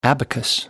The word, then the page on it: abacus: American English